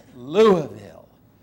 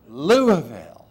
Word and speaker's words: Louisville: She said, it's Louisville, Louisville. And she made me pronounce it several times so I would get it right.